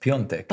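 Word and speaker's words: Piątek: It's spelled Piatek, but it's pronounced Piontek.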